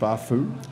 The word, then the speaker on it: Spafoo: So you got this app called, is it pronounced Spafoo? – That's it. You got it. – It's S-P-A-F-O-O.